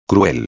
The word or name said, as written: cruel